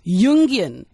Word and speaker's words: Jungian: First of all, let me just say, did I pronounce Jungian right? Because some people say Jung. – That's right. Jung, it's Jung. – Jungian. – Yeah, it's okay. From Carl Jung.